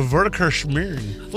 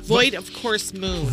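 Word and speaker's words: void-of-course moon: A Voidacurse moon. How do you say that again? – Voidacurse moon.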